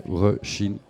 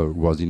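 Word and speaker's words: Róisín: Rushin Murphy. Et pas Rosin Murphy. Voilà. Comme Rosin Murphy.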